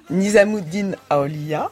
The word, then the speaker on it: Nizamuddin Aulia: Nizamundi Aliya. Alors, qui est-il? Et déjà, corrigez-moi la prononciation. – Nizamuddin Aulia, c'est un saint soufi du XIVe siècle.